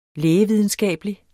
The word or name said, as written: lægevidenskabelig